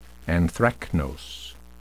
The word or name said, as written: anthracnose